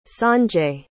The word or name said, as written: sanjay